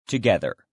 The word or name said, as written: together